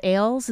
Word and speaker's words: Ailes: And, I mean, for instance, is it Roger Ailes? Is that how you pronounce his last name? – Yes. Mm-hmm.